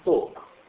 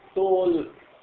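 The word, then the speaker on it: talk: يعني حتى مثلا بعض العلماء بيقولوا مثلا توك، كلمة توك باللغة الإنجليزية لاحظ الحرف اللام ما بن لا يلفظ. طب ليش لا يلفظ وبيكتبوه؟ ليش؟ في قصة، في سر. لو تلفظه ايش بصير؟ تول هم مفيش عندهم تشكيل، احنا في عندنا تشكيل، لو تشكلوا بصير طلقة من الطلاقة وطلاقة اللسان والطلق.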